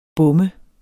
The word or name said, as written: bom